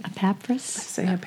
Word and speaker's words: Epaphras: He has spoken to someone named Epaphras. Am I saying his name right? – Epaphras.